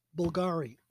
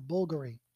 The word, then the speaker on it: Bulgari: One would be Bulgari. Or Bulgari, and it's spelled B-V-U-L, B-V-L-G-A-R-I, or B-U-L, or V-L. You know, they do the Roman numeral kind of U as a V.